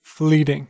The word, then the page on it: fleeting: American English